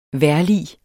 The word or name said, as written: VEJRLIGENE